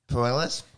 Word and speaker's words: Puelles: How do you say this bloke's name? – Claudio Puelles. – Puelles, very much leg locks was what he was after.